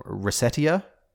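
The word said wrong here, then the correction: Recettear: I think is the name, on Steam? – Is it reciteer? – Reciteer, yes. Yeah, it's a...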